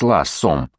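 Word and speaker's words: Law: Вот откуда дружба регента с Лассом. Прижане произносили фамилию Лоу как Ласс.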